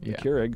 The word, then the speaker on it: Keurig: Keurig.